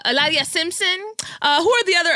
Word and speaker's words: Hilaria: So that's how she pronounces Hillary. I didn't know that Hillary was Hilaria.